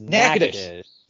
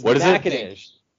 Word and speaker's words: Natchitoches: So the way you say that is Natchitoches. – Yes. – There you go. I got it. So it's Natchitoches.